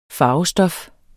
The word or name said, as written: farvestof